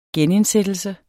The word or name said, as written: genindsættelse